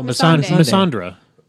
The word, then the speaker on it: Missandei: How do you pronounce her name? Missandei? – Oh, Missandei. Missandra. – No, there's no R. – Yeah, or an A.